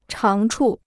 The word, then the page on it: 长处: cháng chù